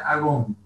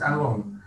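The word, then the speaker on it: agón (ἀγών): en griego se pronuncia agón. Eh, no es muy difícil, simplemente es agón.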